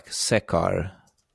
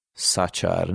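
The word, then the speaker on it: Sachar: Ez egy elég uh, híres és és sikeres könyv volt, azt hiszem 90-es évek végén jelent meg, Louis Szekár, Szacsár, nem tudom, hogy kell kiejteni. Szerintem Szekár. – Szekár, 98-ban jelent meg, mindenféle díjat is nyert, és akkor hát nem is lehetett kérdéses, hogy jöjjön a filmes megfilmesítés.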